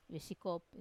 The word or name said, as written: viesikop